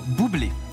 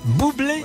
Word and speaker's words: Bublé: Bublé. – Bublé. – Voilà, un artiste pop jazz américain. – Alors ça m'avait échappé, Michael Bublé. Ça s'écrit comment? – B-U-B-L-E. – Voilà. B-U-B-L-E.